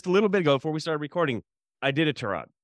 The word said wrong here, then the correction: Iditarod: I did a rod.